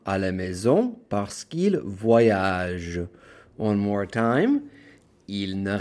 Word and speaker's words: voyagent: Parce qu'ils voyagent. Same thing. ILS, VOYAGENT. Totally skip the ENT and end it on the soft G.